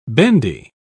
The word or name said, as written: bendy